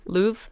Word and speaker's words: Louvre: How do you say the museum name? – Louvre.